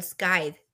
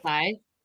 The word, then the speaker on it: scythe: Scythe. Scathe. No, scythe. – Scythe. – What has it been? Sai. Like the Grim Reaper weapon.